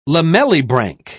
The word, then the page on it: lamellibranch: British English